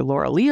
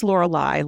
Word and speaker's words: Lorelai: I finally figured out how to pronounce it because Vicky said it. I always thought it was either Laura Lee or Lorelai. It is Lorelai, like the Gilmore Girls, for dinner.